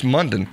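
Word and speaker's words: Mondon: It's Munden. Rhymes with London, according to that.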